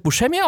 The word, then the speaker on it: Buscemi: Und er hat sich offensichtlich, wie auch seine Eltern, falsch ausgesprochen, bis er dann mal in Sizilien war und festgestellt hat, die werden ja alle geschrieben wie ich. Ah, das wird Buscemi ausgesprochen. Gut zu wissen.